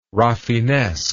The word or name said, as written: rafinesque